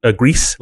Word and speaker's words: Gris: Well, it's the French word for gray, oh, okay. – Which can be pronounced gris. But also grease, like oil. – Yeah. – Anyway, that's how the developers pronounce it.